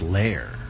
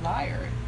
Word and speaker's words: lyre: Although I believe it's pronounced lair. Is it? A lair? It's a lair. – The thing that you play? – Yeah. – Liar. Oh, it's a liar. L-Y-R-E. Oh, now I get it. Now I finally get it. – L-Y-R-E.